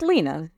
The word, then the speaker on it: Lena: It's Lena.